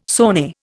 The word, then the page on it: sone: Indian